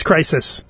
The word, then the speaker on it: Crysis: The game is Crysis, C-R-Y-S-I-S.